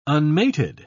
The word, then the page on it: unmated: British English